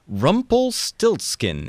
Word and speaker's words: Rumpelstiltskin: And his name is, I've got to pronounce this right, Rumpelstiltskin. Is that how you pronounce it? Rumpelstiltskin? – Got it. Yes. Got it exactly.